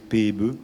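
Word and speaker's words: Pääbo: Et j'entends qu'il y a une exposition sur l'homme de Néandertal euh, suite aux découvertes de cet homme qui s'appelle Svante Pebe. E. Ça s'écrit pas à beau, mais on prononce Pebe e en suédois.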